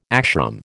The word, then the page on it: ashram: American English